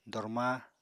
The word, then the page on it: dormaa: apatani